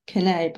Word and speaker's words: Cane: Especially because that word, he's like cane, you're just so used to saying like, oh, that's how you say it, but it is if it's an Italian dog, Canet.